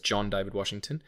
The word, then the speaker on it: John David Washington: I just wanted to get his name right because I was going to say David Washington, but it's John David Washington.